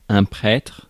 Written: prêtre

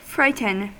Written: frighten

